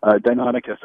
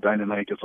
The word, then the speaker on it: Deinonychus: That one, there's a couple of variations, but that was the inspiration for Velociraptor in Jurassic Park. So Deinonychus, I guess, is the way that I usually go with it. Deinonychus also works.